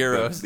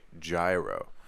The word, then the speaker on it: gyro: Euros. – Like the sandwich also. – Euros. – It is pronounced Euro, right, that sandwich? I've heard people say it gyro.